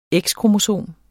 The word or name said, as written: X-kromosom